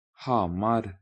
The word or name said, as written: Hamar